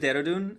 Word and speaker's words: Dehradun: In a rural part of India that is south of Tibet called the, – The city's name is Dehradun and the state is called Uttarakhand.